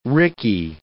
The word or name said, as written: rickey